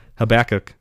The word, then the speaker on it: Habakkuk: So in Hab- Hab Habakkuk, oh my gosh, Habakkuk, that's how you say it in the Old Testament.